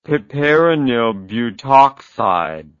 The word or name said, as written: piperonylbutoxide